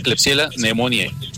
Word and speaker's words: Klebsiella pneumoniae: Se pronuncia Klebsiella pneumoniae. Son nombres en latín y así se pronuncia.